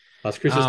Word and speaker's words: Las Cruces: Las Cruces, New Mexico.